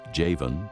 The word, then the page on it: Javan: pronounce with 'y' instead of 'j'